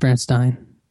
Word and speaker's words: Berenstain: It's pronounced Berenstain. – Berenstain.